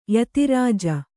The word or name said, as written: ಯತಾರಾಜಿ